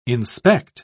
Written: inspect

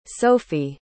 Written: Sophie

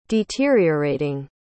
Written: deteriorating

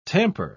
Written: tamper

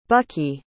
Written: bucky

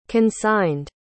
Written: consigned

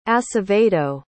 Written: acevedo

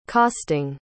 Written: costing

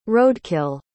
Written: roadkill

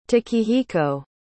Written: takehiko

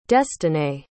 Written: destinee

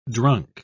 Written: drunk

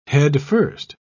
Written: headfirst

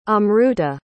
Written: amruta

